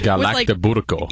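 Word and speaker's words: galaktoboureko: Galacturberko. – Yeah, it's like a custard with like pastry. That was fantastic. That was better than how I said it.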